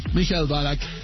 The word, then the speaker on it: Michael Ballack: ¿Cómo se dice bien? ¿Michael Ballack? – Michael Ballack, sí. – Michael Ballack, el futbolista que en ese momento jugaba en el Bayer Leverkusen, que había tenido una triste historia ese año.